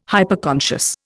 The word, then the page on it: Hyperconscious: Indian